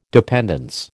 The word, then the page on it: dependence: American English